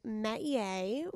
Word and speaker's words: métier: It is métier, which means a vocation, trade, an area of activity in which one excels, a forte.